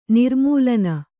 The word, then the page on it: ನಿರ್ಮೂಲನ: nirmūlana